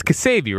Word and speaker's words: Ksebe: Yeah, it's Ksebe, right? – Yes. – So why are you saying K-S-E-B-E? – That's how you spell it.